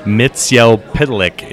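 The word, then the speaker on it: Mxyzptlk: I pronounce it Mr. Mixelplick. But what is the real pronunciation? – Mitziel Pitalik is how they've been pronouncing it lately, but uh, there there are YouTube examples of both.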